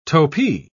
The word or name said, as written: topee